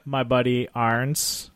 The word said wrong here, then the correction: Aherns: Aherns. – Yes, him. For all you listeners at home, doesn't know how to say his name now. – My buddy Aherns. – Perfect.